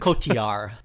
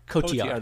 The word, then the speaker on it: Cotillard: How do you say it? – Cotillard. – Cotillard, that's what I thought.